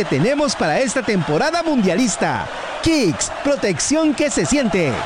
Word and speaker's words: Kixx: Protege tu moto con Kicks, K-I-X-X. Aceites sintéticos y semisintéticos para un máximo rendimiento. Síguenos en Instagram, como arroba aceite-kicks. Diego, ¿cómo se escribe Kicks? Ya le digo, señor, se escribe K-I-X-X.